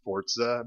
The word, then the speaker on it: Forza: I don't know why Forza is pronounced Forza, but it is.